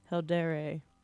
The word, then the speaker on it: Heldere: We have just gone on a carriage to go to Heldere. – Yep. – Perfect. – Perfect. – Like, did I say that correctly? – You did.